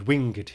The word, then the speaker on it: winged: Note, of course, that the word winged here is pronounced as two syllables.